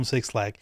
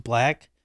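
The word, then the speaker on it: 6LACK: Just call him Black in case he's watching because he gets mad when people call him Six Lack. I remember he did a poster in Philly, I think, like a couple years ago, where it's like Six Lack pronounced Black.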